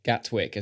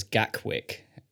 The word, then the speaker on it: Gatwick: I think it's the moment when you mispronounce Gatwick as Gackwick that sends me over the edge.